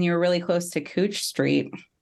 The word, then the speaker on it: Couch: That's literally how it's pronounced. Like the streetcar will announce like 10th and Cooch. And you're like, wow, what's the spelling? – Like couch, like it's the thing you sit on in your house.